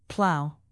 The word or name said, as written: plow